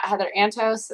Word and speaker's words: Heather Antos: but you can check out, check out me on Twitter at Heather Antos.